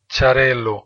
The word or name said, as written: Xarel-lo